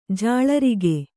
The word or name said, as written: ಜಾಳರಿಗೆ